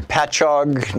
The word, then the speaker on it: Patchogue: Jim in Patchogue, New York. Correct me if I'm wrong in the pronunciation there, Jim. You're on the air with Congressman. – You finally got it right, Thom.